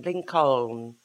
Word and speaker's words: Lincoln: Because I pictured how it's spelled. Lincoln. – Yeah, Lincoln. – Lincoln. Why's an L in the Lincoln? – Lincoln.